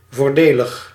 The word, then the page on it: voordelig: /vo:ɾ.də.lɪɣ/